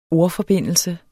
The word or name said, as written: ORDFORBINDELSE